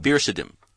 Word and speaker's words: Biricim: B-I-R-I-C-I-M Wilkerson. – Virisim.